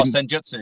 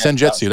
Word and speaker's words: Senjutsu: Oh, Senjutsu. Yeah, Senjutsu.